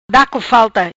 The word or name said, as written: Da co falta